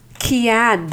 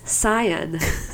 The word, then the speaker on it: cyan: And actually, I would be the Ted Mosby of the group because I'm the one who was always like, it's, you know, it's not pronounced as Kian, Dan, it's Cyan.